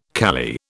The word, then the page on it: kali: British English